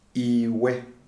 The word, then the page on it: Iwe: Shona